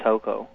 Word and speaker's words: Tocco: No, he may have been referring to Tocos. We had some friends named Toco. – Oh, okay, I'm saying it wrong then. T-O-C-C-O? – Yes, Toco.